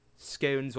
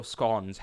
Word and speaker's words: scone: Scones or scones, however you pronounce it. We're not going to get into that. – We actually googled this in computer science. The English pronunciation is scone.